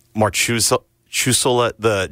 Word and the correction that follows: Marchessault: Jonathan Marchessault. – Marchessault, yeah.